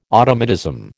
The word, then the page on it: automatism: American English